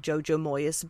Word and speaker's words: Jojo Moyes: Och där har de haft med en av Jojo Moyes böcker. Så de pratade om henne. Jag säger, ja, det är så hon uttalas. – För jag var ju lite inne på Moyes.